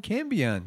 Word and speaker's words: Cambion: Anyway, it has come to my attention recently that I am, how do you say it? Kale, I don't remember. It is a big chameleon. Chameleon. – I'm sure you're Cambion. – Cambion. Yes, you're a Cambion.